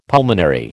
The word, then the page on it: pulmonary: American English